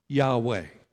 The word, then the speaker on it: Yahweh: So in the Old Testament, God revealed himself to Moses with the name that we assume is pronounced Yahweh.